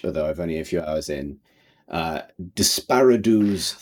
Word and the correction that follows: Desperados: Unfortunately, that I hope that pronunciation has not ruined this game for everybody as it's ruined it for me. Um, it, we're talking about Desperados 3.